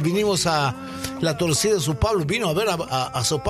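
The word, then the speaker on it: São Paulo: Dice Sao Pablo. ¿Está bien dicho así? – Perfecto. Lerninho fala portugués extraordinario.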